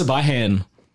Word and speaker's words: Siobhan: Like Siobhan, but it's, oh, but it's like spelled like with a B in the middle. – It's Sabahan.